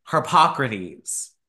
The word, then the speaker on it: Hippocrates: Hippocrates.